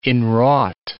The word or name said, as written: in-wrought